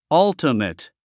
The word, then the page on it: ultimate: ʌ́ltəmət; アウチメット